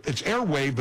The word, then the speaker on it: Airwaav: Airwave is spelled, it's airwave, but it's spelled A-I-R-W-A-A-V. So remember that W-A-A-V spelling. I'm